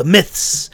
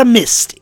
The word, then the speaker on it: myth: And I'm going to end it up by talking about the myths. The myths. Did I say that right? Myths. M-Y-T-H. I'm just saying, wow, that S-T is coming out there, huh? Not a mist, it's a myth.